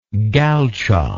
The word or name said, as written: galchic